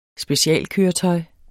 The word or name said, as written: specialkøretøj